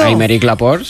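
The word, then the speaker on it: Aymeric Laporte: Améric Laporte, sí. – Ay, qué bien pronunciado. – Es francés, claro.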